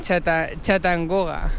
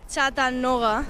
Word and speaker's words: Chattanooga: Chattanooga. – Chattanooga.